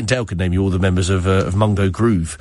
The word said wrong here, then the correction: Mango Groove: I'm sure. Mango Groove. Sorry, I can't get the name right.